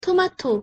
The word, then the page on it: 토마토: Korean